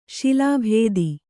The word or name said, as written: ಶಿಲಾಭೇದಿ